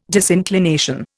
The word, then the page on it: disinclination: Indian